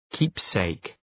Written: keepsake

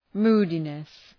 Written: moodiness

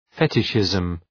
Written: fetishism

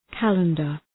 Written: calendar

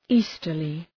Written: easterly

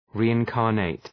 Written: reincarnate

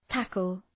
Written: tackle

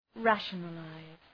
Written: rationalize

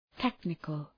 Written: technical

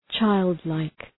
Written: childlike

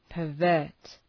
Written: pervert

